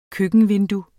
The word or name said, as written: køkkenvindue